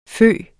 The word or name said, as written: fyge